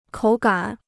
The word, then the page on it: 口感: kǒu gǎn